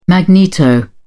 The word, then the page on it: magneto: British English